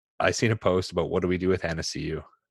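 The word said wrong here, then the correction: Athanasiou: And like, what do we do about your inability to say his name? – Athanasiu, sorry. – Athanasiu. – Athanasiu, yeah.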